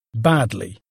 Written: badly

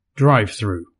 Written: drive through

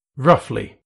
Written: roughly

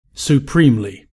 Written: supremely

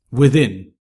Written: within

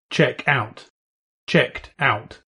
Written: check out

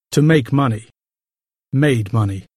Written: make money

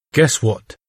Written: guess what

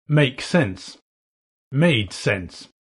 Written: make sense

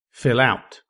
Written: fill out

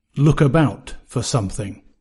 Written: look about for

